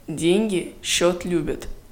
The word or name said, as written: деньги счет любят